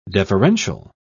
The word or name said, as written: deferential